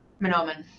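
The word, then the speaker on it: Manoomin: That, and the story begins during Manoman, or Manuman, is, how is that pronounced? Is it Manoman? – Manoman. – Yeah, Manoman. Maybe talk about the significance of Manoman for both the characters in your book and people today.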